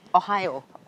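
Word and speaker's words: Ohio (おはよう): I know, actually, it does sound like Ohio, and I think you say it like the Ohio, place. Like the state. – But it's Japanese for good morning, Ohio.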